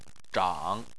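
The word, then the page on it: zhang: tone 3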